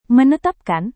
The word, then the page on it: menetapkan: Indonesian